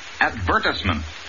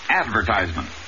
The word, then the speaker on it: advertisement: In order to find her diamond, she inserted an advertisement in the classified section of the newspaper. – The word? Advertisement. Accent on the second syllable, not advertisement. And remember, the I in this word is also short.